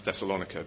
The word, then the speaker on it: Thessalonica: and which I'm going to pronounce Thessalonica because it's much easier to say.